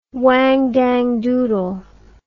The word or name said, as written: wang dang doodle